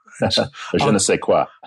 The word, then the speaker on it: je ne sais quoi: Je ne sais quoi.